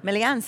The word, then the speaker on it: Melean: Melean, sí.